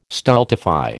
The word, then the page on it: stultify: American English